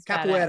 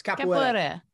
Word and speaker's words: capoeira: I'm saying it wrong, but it's capoeira. – Badass. – It's capoeira.